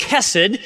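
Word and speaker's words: chesed: Now, the word kindness is a beautiful Hebrew word, which is pronounced kesed, and it means loyal love.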